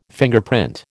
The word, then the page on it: Fingerprint: American English